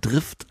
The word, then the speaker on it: Drift: Aber deutsch ausgesprochen, nicht Drift, sondern Drift.